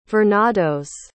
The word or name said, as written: vernados